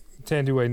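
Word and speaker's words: Thandiwe: Tanduay Newton. – Is that how you say it? – Yes.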